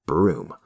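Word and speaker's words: Bruttenholm: His last name is spelled Brutenholm, but we're assured in a nice text box that it's pronounced Broom,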